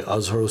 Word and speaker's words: Azhar: Azhar Osman. You can find me on the internet at Azhar Comedy. That's spelled A-Z-H-A-R Comedy.